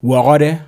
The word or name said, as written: Wawhawde